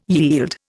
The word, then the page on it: yield: Indian